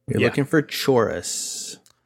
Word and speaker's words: Chorus: I know we weren't sure quite how to say it because it looked like it was a Chorvs. But the official pronunciation is Chorus, and it's supposed to be a U. I don't know why it looks like a V.